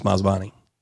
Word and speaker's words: Masabni: Massa Benia, but it's M-A-S-A-B-N-I. – Okay. – And I think if I recall, his pronunciation is Masbani. – Okay. But M-A-S-A-B-N-I, Joseph Masbani, Texas.